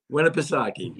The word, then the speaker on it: Winnipesaukee: Winnipesaukee. Yeah, yeah Winnipesaukee.